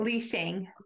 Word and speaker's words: Li Shang: For Lei, was it Ling Shay? How do I say it? – Li Shang. – Li Shang. I got it backwards.